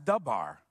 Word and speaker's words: davar: That word matter is the Hebrew word spelt davar, but pronounced dabar.